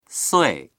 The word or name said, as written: sui4